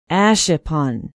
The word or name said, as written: ashippun